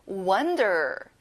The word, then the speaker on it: wonder: Wonder. 친구들, 아 소리를 내는 거예요.